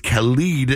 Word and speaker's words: Khalid: They're teaming up with, I said Khaled earlier. It's Khalid, if you want to pronounce it properly, Drew.